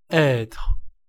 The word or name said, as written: être